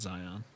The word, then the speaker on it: Zion: Mount Zilion, huh? – I said it wrong? – Yeah, I think it's Mount Zion. – Oh, that was supposed to be Zion? Come on, bro. – It's definitely supposed to be Mount Zion.